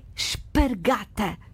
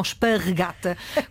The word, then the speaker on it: espargata: Resumindo, a pronúncia correta é espargata. – E não esparregata, como muita gente diz e como se ouve por aí muitas vezes.